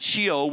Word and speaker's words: Sheol: It's a Hebrew word that is pronounced, we would pronounce that Sheol, which means the world of the dead.